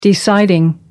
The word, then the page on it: deciding: American English